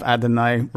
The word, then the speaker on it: Adonais: Oddly enough, Adonais rhymes with El Shaddai, one of the reasons that song might have been in there.